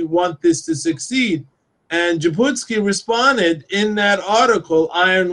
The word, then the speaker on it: Jabotinsky: Do I pronounce his name correctly? – Jabotinsky.